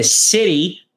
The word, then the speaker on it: city: Não é siri. É c. É como se fosse um e. City. City. E o t, que tem som de r.